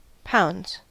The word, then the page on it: pounds: American English